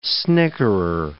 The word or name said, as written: snickerer